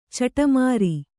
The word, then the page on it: ಚಡಿಮರಿ: caḍi mari